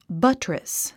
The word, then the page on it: buttress: bʌ́trəs; バットレス